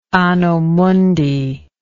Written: anno mundi

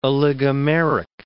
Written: oligomeric

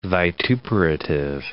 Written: vituperative

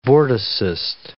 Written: vorticist

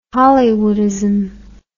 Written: Hollywoodism

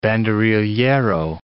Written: banderillero

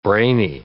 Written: brainy

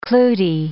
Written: Clootie

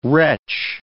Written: retch

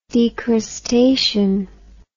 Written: decrustation

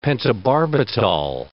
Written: pentobarbital

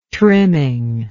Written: trimming

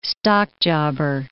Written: stockjobber

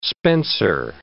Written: spencer

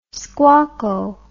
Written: squacco

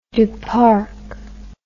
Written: Duparc

